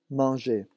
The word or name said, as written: manger